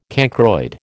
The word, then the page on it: cancroid: American English